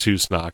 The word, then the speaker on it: Tusnak: But Tuknak is included. – Yes, it is. Tusnak is included. And that is how it's pronounced, Tusnak, not the way I did it back in the day.